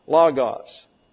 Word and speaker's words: logos: Logos, L-O-G-O-S. These are omicrons here, sound like a short A in the English, logos.